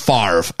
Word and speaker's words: Favre: Explain to me, just on behalf of all Canadians, how is it Favre and pronounced Favre? I just want to know that. How did the R come before the V in the pronunciation? – No idea.